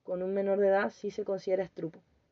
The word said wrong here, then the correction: estupro: estupro, estupro. Siempre se me olvida cómo se dice esa palabra. Estoy muy boluda hoy. Estupro o estrupo. Estupro, ya.